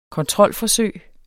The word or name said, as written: kontrolforsøg